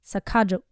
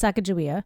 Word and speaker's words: Sacagawea: Yeah, and that the J sound is actually a hard G, and that the you put the emphasis on the second syllable, not the first. So Sacagawea. So there's a lot of like historians that feel like that's the right pronunciation.